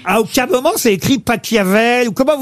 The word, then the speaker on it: Pachelbel: Canelloni, c'est Pachelbel. – S'il est allemand, ça se dit Pachelbel.